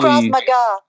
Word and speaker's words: Krav Maga: You studied, I don't even know how to say this, Krav Maga, the Israeli... – Krav Maga.